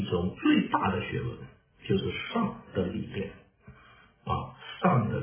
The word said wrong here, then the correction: 善: 我说的这个上是 SHAN，是 good 啊，良善，良善的理论，良善的理念，在 505 啊， 505，诸葛那里说，善的理念是最大的知识问题。